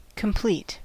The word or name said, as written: complete